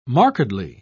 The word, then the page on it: markedly: British English